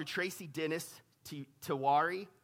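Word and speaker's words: Tracy Dennis-Tiwari: Listen to the Dr. Tracy Dennis Tiwari, is how I say her name.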